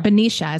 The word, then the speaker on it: Benicia: Benicia, is that how you say it? – Yeah.